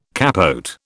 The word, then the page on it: capote: British English